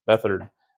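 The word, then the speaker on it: Beathard: Bethard.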